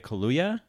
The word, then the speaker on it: Kaluuya: Daniel Kaluuya, I'm saying his last name wrong. Daniel Kaluuya, am I saying that right? – You're right.